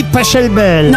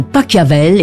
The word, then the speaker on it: Pachelbel: Ariel, que vous ayez dit Pachelbel.